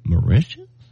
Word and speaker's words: Mauritius: Deborah Sullivan Johnston wants to go to, and I had to look up how to say it, Mauritius. – Mauritius? – And it's in Eastern Africa.